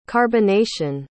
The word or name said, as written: carbonation